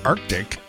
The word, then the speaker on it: Arctic: The word isn't Arctic. The C just isn't, you know, silent because you decided it's too hard to say Arctic. The C is not silent. It's an arc. That's where the word comes from.